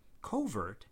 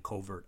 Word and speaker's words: covert: Covert tends to have its stress on the first syllable when it precedes a noun, as in covert operations. But the stress tends to move to the second syllable when it stands alone, as in the operation was classified as covert. It's likely that the covert pronunciation will eventually vanish into the shadows, but it's still considered standard for now.